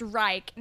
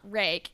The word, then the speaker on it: Reich: He's like, it's pronounced Reich, not rake.